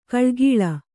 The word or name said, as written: ಘಳಘಳ